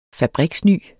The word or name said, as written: fabriksny